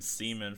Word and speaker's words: Simen: Is it Simon? – Of us were... Simon, yeah. – I've Seaman. Been calling him Seaman forever. – It's spelled S-I-M-E-N. So yeah, Seaman. – Simon. Simon? Yeah, Seaman.